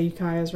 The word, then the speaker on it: Ukiah: Am I pronouncing that right? I have zero idea. – I don't have it in front of me. – Ukiah. – I'd probably say Ukiah's right.